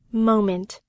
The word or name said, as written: moment